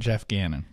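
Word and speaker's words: Geoff Gannon: At Jeff Gannon.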